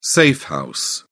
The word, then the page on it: safe house: British English